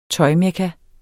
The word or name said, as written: tøjmekka